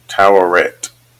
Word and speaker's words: Tawaret: Tawert. Pronunciation. Come on. Here we go. It is spelled, it actually is pronounced, I think, one of the ones you did. Tawaret. So that's it.